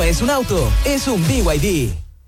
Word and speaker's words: BYD: ¿Los VID? – ¿VID? ¿No es VID? – Nada que ver, se escribe BYD, pero se dice BYD, la marca número 1 de electromovilidad en Chile y el mundo.